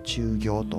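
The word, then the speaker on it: 厨業: 駐業というのは、はい、厨房の厨に、業務の業と書いて駐業と読むんですけれども、教科書、これ舞鶴で有名なあのレシピが載ってるんです。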